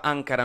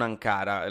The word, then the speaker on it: Ankara: Prima di finire ci tenevo a specificare che ho sbagliato, si pronuncia Ankara, non Ankara, la capitale della Turchia, quindi scusatemi, però per il resto, anche oggi grazie per aver ascoltato Vitamine.